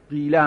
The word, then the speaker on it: قیله: این لغت قیله یه جوری دیگر عرب تلفظش میکند به نام اشمام، قیله قاف کسره داره، وقت عرب یک لحن لهجه خاص دارد، این کسره را میتوند میل بده در تلفظ به سمت زم.